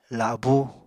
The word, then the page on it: labú: Apatani